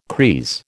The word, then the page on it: creese: American English